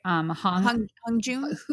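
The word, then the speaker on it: Hongjoong: What's the leader's name? Sorry, I don't pronounce it right. – Hong Jun. – Hong Jun. I would say Hong Jun.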